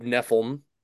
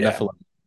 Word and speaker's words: Niphilium: Did I say that right? – Launching as the first time, yeah. Club Niphilium. – Niphilium, yeah. I guess I did not put the right emphasis on the right syllable.